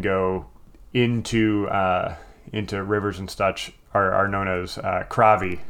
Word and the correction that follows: karvi: Kravi, will you spell that? – K-R-A-V-E or K-A-R-V-I. Carvi. – Carvi.